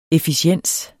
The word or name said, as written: efficiens